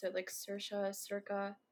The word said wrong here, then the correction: Circe: Oh, Circe? – Circe. Oh, my God. I butchered that.